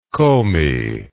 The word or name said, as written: komi